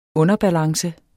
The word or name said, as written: underbalance